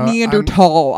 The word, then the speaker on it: Neanderthal: Or is it Neanderthal? I've heard that in documentaries. Neanderthal. – Yeah. That's the technical pronunciation.